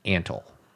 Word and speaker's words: Antle: So that's Doc Antle.